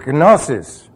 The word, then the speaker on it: gnosis: Now that information then is cycled down into this area where it becomes gnosis. Not gnosis. It's gnosis. You say, but you did put a G sound in there. Yeah, but it's almost silent. Almost silent. What is a pneuma? What is this? How do you pronounce that? P-N-E-U-U?